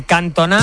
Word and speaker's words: Cantona: Mira, nos decía un oyente, Cantona o Ginola no tenían acento y se acentuaban en la última sílaba. Los acentos correcto. Del francés no funcionan como los del español.